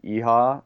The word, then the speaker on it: Eha: You can find me there at Brian Eha. My last name is spelled E-H-A.